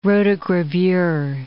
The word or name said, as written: rotogravure